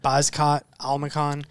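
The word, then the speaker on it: Bekzat Almakhan: Umar Nurmagomedov defeats Bezkat Almakhan. Am I saying that right? – Yeah, I believe that's how that... – Bezkat Almakhan. – Yeah.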